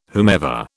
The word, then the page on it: whomever: British English